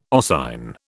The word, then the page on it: oscine: British English